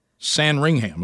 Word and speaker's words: Sandringham: It looks like Sandringham is what it looks like.